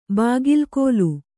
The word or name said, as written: ಬಾಗಿಲ್ಕೋಲು